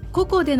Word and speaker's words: ココ: ここん、いや、あのね、舞台となるゲストハウスの名前がカタカナでココなんですよ。だから、まあ、監督に確認してないんですけれども、こう発音するのが正解なんだと思います。 – ではココでの話からご紹介お願いします。